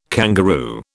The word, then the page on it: kangaroo: British English